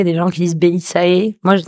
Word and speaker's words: Bellisae: Alors, ta société s'appelle Belisae. Je prononce bien Oui.